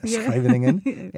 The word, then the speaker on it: Scheveningen: I got the pronunciation down, Scheveningen.